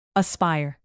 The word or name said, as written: aspire